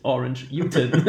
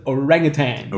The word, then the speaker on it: Orangutan: Aber dann bin ich drauf gekommen, dass das Wort auf Englisch Orangutan ist. Und nicht Orange Utan. Und für mich immer noch unerklärlich, dass es ein Orangutan ist.